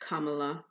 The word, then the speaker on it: Kamala: The name Kamala, pronounced Kamala as in comma dash la, is of Sanskrit origin.